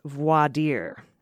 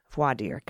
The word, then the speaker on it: voir dire: Just a pronunciation note of voir dire, which is voir dire, as in Roger Stone's attorneys can't use their own fuck-up during voir dire.